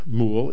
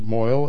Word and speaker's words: mohel: but it's based on Moel. It's Moel. And it's spelled M-O-H-E-L, but they pronounce it Moel.